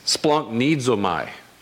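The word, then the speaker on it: splanchnizomai: It's kind of a funny pronunciation. It's splanknizomai.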